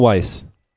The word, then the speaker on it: Weisz: Rachel Weisz. – Yes, Weisz. Rachel Weisz. – Rachel Weisz. There we go. – I learned how to say it.